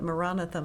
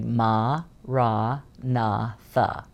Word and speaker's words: Maranatha: So when we say it, when we're meditating, we say it as four syllables, and we say Ma Ra Na Tha. Ma Ra Na Tha.